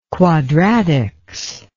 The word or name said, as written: quadratics